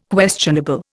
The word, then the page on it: questionable: Indian